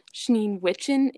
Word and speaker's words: Schneewittchen: But it's basically like it looks like the word Snow White, but it's Schneewittchen.